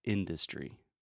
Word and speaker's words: industry: So industry, the stress is first on the first syllable, industry. And the second syllable is the schwa, in the, du uh. But for some reason, Trump puts the stress on the schwa, which never happens in English.